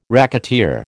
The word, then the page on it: racketeer: American English